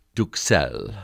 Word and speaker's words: duxelles: The word is duxelles.